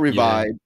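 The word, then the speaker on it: Revib3d: They're called Revived, and it's spelled R-E-V-I-B, the number 3 and then a D. So the last E is a 3, but they're called Revived.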